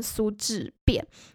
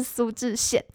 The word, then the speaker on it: 苏志燮: 这个欧巴呢，就是这个苏志燮。我在做这一集之前，其实特特别偷偷去查这个字到底怎么念，因为我之前一直念错，我一直念成苏志变，然后好像有查，所以我现在知道是苏志燮。